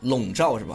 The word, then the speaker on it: 笼罩: 对，像乌云一样啊，这个就是笼罩是吧？ – 对，笼罩。